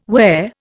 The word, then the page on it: where: Indian